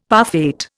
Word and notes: parfait: Indian

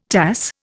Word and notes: Tass: Indian

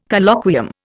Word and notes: Colloquium: Indian

